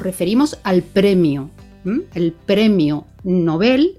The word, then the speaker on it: Nobel: Respetando la pronunciación sueca del apellido de Alfred Nobel, ¿verdad? – Nobel. – Aunque está muy extendido quienes lo pronuncian Nobel, uh-huh, pero se recomienda, de hecho se escribe sin tilde, ¿verdad? Precisamente, uh-huh, por eso, porque es palabra aguda terminada en L.